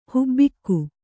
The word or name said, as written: ಹಬ್ಬುಗೆ